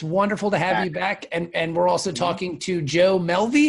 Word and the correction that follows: Mulvey: Did I pronounce that right? – No, you didn't, but it's close. It's Mulvey. – Just Mulvey. Okay.